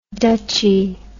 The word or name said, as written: dutchy